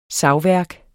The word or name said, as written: savværk